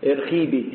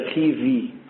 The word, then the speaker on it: ارخيفي: في ناس بتقولها ارخيبي، هي ارخيفي ارخيفي الربع بتاع افيوت ارتخني ارتخني تسئرو اثناء ارخيفي اثناء ارخيفي مش خيبي خيفي. البيتا لما يجي بعدها باول تنطق في مش بي.